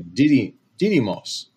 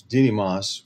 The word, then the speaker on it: Didymos: And so, of course, if this was an asteroid, by the way, the name of this asteroid is Didymos. I always pronounce it incorrectly. Didymos.